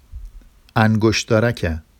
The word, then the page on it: angoʃdɑraca: Vafsi